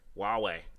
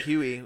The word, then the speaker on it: Huawei: Huawei. – Say it, Huawei. – Huawei. – A lot of people call it Huey, so at least you didn't say that.